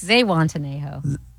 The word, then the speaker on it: Zihuatanejo: It's Zihuatanejo. – Isn't it Z? Z-I? – I don't know. – H-U-A? – Apocalypse.